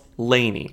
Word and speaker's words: LANY: I eventually thought of the span across the country from LA to New York, and at first I was thinking it would be L-A-N-Y, but people kept getting confused on how to pronounce it when I told them, saying things like L-A and Y. So we're like, forget it. We're calling ourselves Lainey. – But it's L-A-N-Y. Lainey. It's very L-A-N-Y.